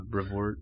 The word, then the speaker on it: Brevoort: So recently, I don't know why it's come up so frequently, but Tom Brevoort, is that how you pronounce that dude's last name? – Yeah, I want to say so, yeah. Brevoort.